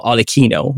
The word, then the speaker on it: Arlecchino: Now, Harlequin, or Arlecchino, which is the Italian way to pronounce it, named primarily after Helikins, which is the French iteration of the wild man.